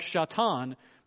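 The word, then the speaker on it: Shatan (שטן): They would have pronounced it something more like Shatan, but the idea is, the description is the word Satan.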